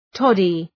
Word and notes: toddy: ‘tɒdı

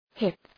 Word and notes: hip: hıp

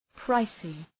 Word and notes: pricey: ‘praısı

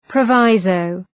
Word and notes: proviso: prə’vaızəʋ

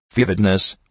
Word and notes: vividness: ‘vıvıdnıs

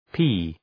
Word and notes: pee: pi:

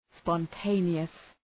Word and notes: spontaneous: spɒn’teınıəs

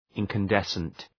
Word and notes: incandescent: {,ınkən’desənt}

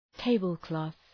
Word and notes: tablecloth: ‘teıbəl,klɒɵ

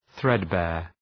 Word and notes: threadbare: ‘ɵred,beər

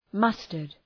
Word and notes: mustard: ‘mʌstərd

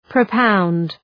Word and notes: propound: prə’paʋnd

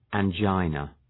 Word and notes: angina: æn’dʒaınə

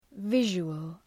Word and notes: visual: ‘vıʒʋəl, ‘vıʒəl

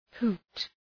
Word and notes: hoot: hu:t

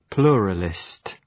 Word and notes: pluralist: ‘plʋrəlıst

